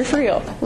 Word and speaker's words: Friel: And that's Mr. Friel. Rhymes with real, which is what he is.